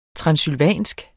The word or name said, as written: transsylvansk